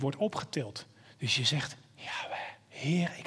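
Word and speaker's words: Yahweh (יהוה): Eentje is, weet je hoe je hem kunt uitspreken? Als je woorden pakt, en heel vaak gebruik je je lippen en je tong, hè? Maar als je Yahweh, als je erover nadenkt hoe je het kunt uitspreken, is als volgt. Yahweh. Je kunt het uitspreken zonder je tong en je lippen op elkaar te doen.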